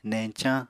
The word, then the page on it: neñcañ: Apatani